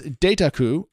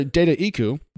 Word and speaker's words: Dataiku: First, two ways to pronounce this, Dataku or Dataiku. Dataku? Hard to say. It's data and haiku smushed is how this word goes together.